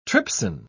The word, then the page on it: trypsin: British English